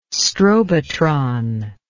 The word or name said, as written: strobotron